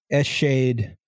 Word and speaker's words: Eschede: Today we're gonna learn about the Eschede train disaster. – I literally changed my Zencaster name to a pronunciation guide for you. – Really? – Yeah, Eschede. – Eschede? – Mm-hmm.